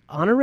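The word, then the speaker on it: Honoré: And then General Honore, am I saying that right? – Yes.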